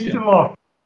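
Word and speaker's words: Kicillof: O atual governador é o Kisilov, né? É isso? É assim que se pronuncia? – Kisilov. Chama-se Axel Kisilov.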